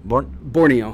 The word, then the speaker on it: Borneo: Borneo.